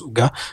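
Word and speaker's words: hygge: C'est le livre du Ige, enfin du Ouga, qui, qui s'écrit Ige, mais qui se prononce Ouga.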